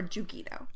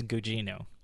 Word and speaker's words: Gugino: It popped up periodically, and I really like Carla Gugino, and is it Gugino or Gugino? – It's neither, actually. I was looking up, it's Gugino. – Gugino. – So hard okay. G, then the soft G.